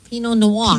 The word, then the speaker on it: Pinot Noir: He had said to the waiter, I'll have the Pinot Noir. I said, I think the R, you pronounce the R, don't you, in Pinot Noir? – Pinot Noir.